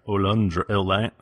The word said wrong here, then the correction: Aludra: How do I say it? – Aludra. – Aludra.